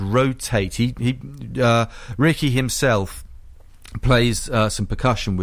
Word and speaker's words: Rotait: The two of them go together, you know, acoustic guitar and cello, beautifully played, and they're called Rotate, only it's spelled R-O-T-A-I-T.